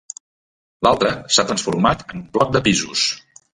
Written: transformat

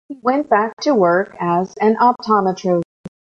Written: optometrist

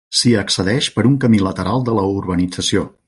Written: accedeix

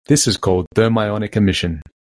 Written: thermionic

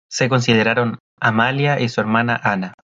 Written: consideraron

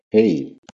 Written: Hey

